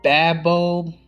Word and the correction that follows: baobab: Probably pronouncing that wrong. It's B-A-O. Bab. Baobab. There we go.